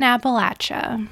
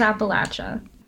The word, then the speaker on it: Appalachia: She says, from my experience going to college in Appalachia, it was drilled into me that the correct pronunciation is with latch in the middle, like I threw an Appalachia. So Frankie wins. – Okay, we'll go with Appalachia.